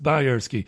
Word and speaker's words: Bajerski: Now, Bajerski is spelled Bajerski, but it's pronounced Bajerski.